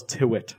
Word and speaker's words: Tyrwhitt: He told me it's actually Charles Tyrwhitt. So I apologize for having mispronounced it for so long.